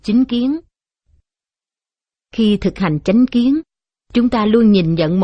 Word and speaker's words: chánh kiến: Một, thực hành chánh kiến. Chánh kiến đôi khi cũng đọc là chính kiến, với ý nghĩa là quan niệm, cách nhận hiểu vấn đề một cách chân chánh.